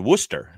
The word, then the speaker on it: Worcester: Well, you're talking to someone that a New Englander where, you know, where the Red Sox AAA team is in a town called Worcester. And it's pronounced like rooster, but with a W instead of an R.